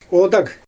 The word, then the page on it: odag: Xyzyl